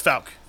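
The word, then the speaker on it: Fouke: Well, you know, that fucking movie that we watched on, Everybody I've ever heard say is pronounced it Falk. – Was it Falk? – Yes. – Did they pronounce in both films we watched? – Yes. It's Falk.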